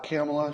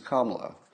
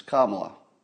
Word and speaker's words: Kamala: Don't call her Kamala. No, no, no. She's not Kamala, she's Kamala. We must all learn to pronounce it properly. It's not Camelot, it's not Camelot. It's not Camelot, it's not Camela. It's Kamala.